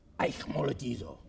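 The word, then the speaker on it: eikmolatizo (αἰχμαλωτίζω): And that Greek word there is eikmolatizo. I have a software program, and it says eikmolatizo. Teaches me how to pronounce it.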